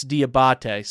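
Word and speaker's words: Diabaté: I think Mahmoud Diabite is, actually might be pronounced, I could be saying that wrong. It's Diabate. Sorry, not Diabite. So it's Diabate. Mahmoud Diabate is the name pronunciation.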